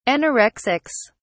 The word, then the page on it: anorexics: British English